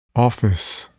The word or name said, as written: office